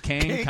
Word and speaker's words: Kang: Kong? – Kong. It's Kang.